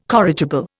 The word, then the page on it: corrigible: Indian